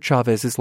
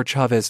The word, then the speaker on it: Chavez: We were saying Chavez. Gretchen, you're right. And here to back you up is spokesman at the Cesar Chavez Foundation. – The correct pronunciation of his last name is Chavez.